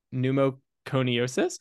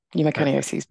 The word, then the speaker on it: pneumoconiosis: Her clinical interests include occupational lung imaging, specifically in the areas of silicosis and other pneumoconiosis. How do you say it? – Pneumoconiosis. – Pneumoconiosis.